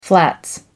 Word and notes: flats: British English

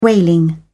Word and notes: whaling: British English

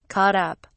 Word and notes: caught up: British English

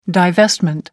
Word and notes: divestment: American English

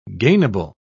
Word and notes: gainable: British English

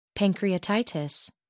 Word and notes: pancreatitis: American English

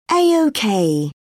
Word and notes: a ok: British English

